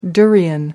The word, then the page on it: durian: American English